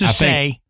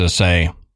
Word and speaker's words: assay: Now the assay office, I think it's a say. – Yeah, I think I pronounced it wrong earlier, but I think it's a say.